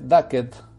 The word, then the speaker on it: Get Duked: Se llama Get GetDucked eh, Get sería eh, la pronunciación exacta para que lo encuentren cuando lo busquen en el buscador, valga la redundancia, de Amazon. Se escribe Ducked, D-U-K-E-D. E Get como Get, G-E-T.